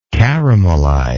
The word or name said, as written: caramelization